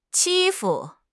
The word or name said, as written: 欺负